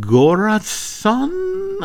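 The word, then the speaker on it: Göransson: Ludwig Goransson. I'm hoping I got that right. – That sounds good.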